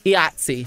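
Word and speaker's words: IATSE: Now, how do you pronounce, like, this acronym is IATCE, but isn't it pronounced a certain way? – Yahtzee.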